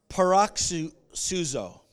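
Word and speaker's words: paroxyno: The transliteration is pronounced paroxuzo.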